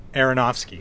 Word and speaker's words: Aronofsky: Darren Aron- Aronofsky. – Yeah. Uh, Aronofsky.